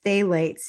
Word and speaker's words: phthalates: Phthalates, it's pronounced.